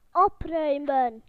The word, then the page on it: to tidy: British English